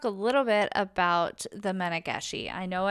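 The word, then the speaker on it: Mannegishi: It's M-A-N-N-E-G-I-S-H-I, Menageshi.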